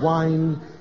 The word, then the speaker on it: whine: It's not pronounced the same way as wine. Whine and wine.